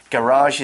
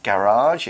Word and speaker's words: garage: Garage is spelled G-A-R-A-G-E. Garage, it's a noun. People in the UK will say garage. Okay, so for me, I would naturally say the time machine is in the garage, but I believe Americans pronounce it more like garage.